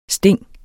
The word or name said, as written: Stingene